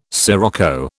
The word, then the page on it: scirocco: British English